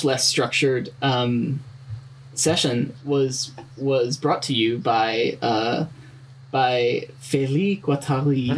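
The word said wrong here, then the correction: Félix Guattari: Felix Guattari.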